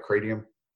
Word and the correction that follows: kratom: Kratom. – Kratom.